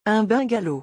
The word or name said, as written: Un bungalow